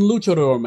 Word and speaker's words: luchador: luchador mask. Luchador. I'm saying that correctly. Yes.